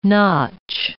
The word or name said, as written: notch